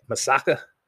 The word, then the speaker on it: Misaka: If that is the case, then what I'm going to ask you to do is just go ahead and what do you say his name? Misaka.